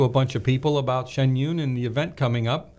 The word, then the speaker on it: Shen Yun: And for the next hour, we'll be talking about Shen Yun. Did I say that right? I know I messed it up last year. – No, you got it right. Shen Yun.